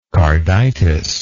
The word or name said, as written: carditis